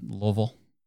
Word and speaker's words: Louisville: At this guy's wedding, he's from Kentucky and his wedding was in Louisville.